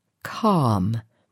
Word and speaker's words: calm: Let's look at the word calm. It has a silent letter L but we just hear calm.